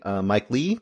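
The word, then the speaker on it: Mike Leigh: Naked, 1993, Mike Lee. Mm. I think I'm saying his name correctly. – Yes, you are.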